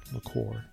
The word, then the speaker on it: liqueur: Jump on all the dessert cake, liquor cakes, those liqueur cakes. Liqueur. – Careful how you say it. – Yeah, I know. – One is totally different.